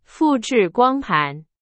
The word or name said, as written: 光盘